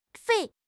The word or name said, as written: 番